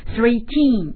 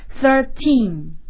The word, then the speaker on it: thirteen: 那么在这里面啊，我要提醒大家，就是十三是稍微有点特殊的啊，它我们不要给它读成 thirteen 啊，我们要说 thirteen。